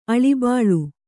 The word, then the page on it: ಅಳಿಬಾಳು: Kannada